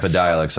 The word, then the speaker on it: Epidiolex: Epidiolex.